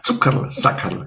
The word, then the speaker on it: Zuckerl-Sackerl: Alle Zuhörerinnen aus Österreich mögen mir verzeihen, ein Zuckerl-Sackerl. – Zuckerl-Sackerl, ganz genau richtig. An der Betonung müssen wir noch ein bisschen arbeiten, aber der Begriff ist absolut richtig.